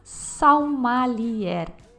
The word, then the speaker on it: saumalier: E esse cara era chamado salmalier. É uma palavra do dialeto provençal arcaico, pronuncia praticamente igual, mas a grafia é diferente. Escreve salmalier mesmo, S-A-U-M-A-L-I-E-R.